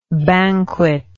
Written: banqueteer

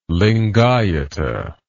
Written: lingayata

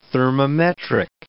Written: thermometric

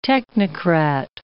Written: technocrat

